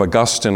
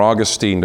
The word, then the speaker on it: Augustine: You know the name of Augustine or Augustine, depending on how you want to pronounce that name, an early church father.